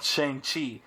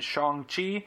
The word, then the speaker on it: Shang-Chi: know I've called it Shang-Chi. But I saw today in an interview with the director that it is Shang-Chi.